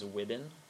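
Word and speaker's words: Wuebben: And so, my last name is Wibben. It's like it's pronounced like ribbon with a W, but it's W-U-E-B-B-E-N.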